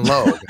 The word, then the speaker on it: Logue: Like rhymes with rogue for Zion Logue.